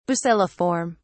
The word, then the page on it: bacilliform: British English